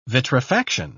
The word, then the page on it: vitrifaction: British English